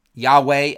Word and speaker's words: Yahweh: Jehovah worship, Yahweh as it's actually pronounced, was a belief in a higher standard of morality.